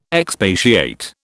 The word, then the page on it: expatiate: British English